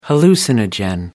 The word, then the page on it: hallucinogen: American English